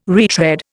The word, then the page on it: retread: Indian